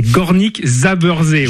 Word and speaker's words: Górnik Zabrze: C'est Górnik Zabrze, le vainqueur de la dernière Coupe de Pologne.